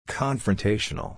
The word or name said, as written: confrontational